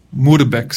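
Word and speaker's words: Moerbeke: Y ahí por un fraile dominico llamado Guillermo de Moerbeck, Moerbeck sería la pronunciación correcta, de la región donde es la Holanda, por ahí, él tradució al latín.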